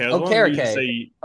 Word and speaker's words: Okereke: Okereke. Okereke. That's how I always pronounce it, phonetically.